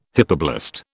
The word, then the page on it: hypoblast: British English